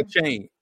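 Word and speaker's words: Achane: A-Chain.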